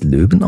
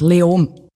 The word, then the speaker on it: Leoben: Spricht man es Leoben aus? Wie spricht man das überhaupt aus? – Fast kein Konsonant. Leom.